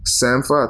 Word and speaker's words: Sampha: Sampha, I think that's how you pronounce it.